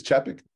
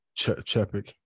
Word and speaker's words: Chapek: How do you pronounce his last name? – I believe it's Chapek. – Chapek. – Yep.